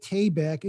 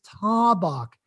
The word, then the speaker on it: Tabak: And someone said, it's not the actor Vic Tabak, it's Tabak. And they even sent me a little video of a Tabak commercial where they were pronouncing it correctly.